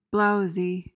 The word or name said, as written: blowzy